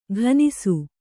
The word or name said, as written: ಘನಿಸು